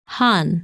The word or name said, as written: Han